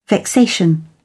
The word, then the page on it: vexation: British English